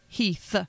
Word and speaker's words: Heath: I think it's the Heath and the Kuh sound, so everybody thinks your last name is Keith, or they don't understand what you just said. Crystal Heath. Try saying Heath like 30 times fast. It's a kind of a difficult pronunciation.